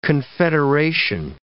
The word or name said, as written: confederation